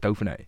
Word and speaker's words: Dauphiné: And did you won the stage at the, is it the Dauphiné? – Yeah. – Is it, I pronounce it Dauphiné? – Yeah.